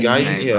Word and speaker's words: Guyton: Guyton, that's how you say it.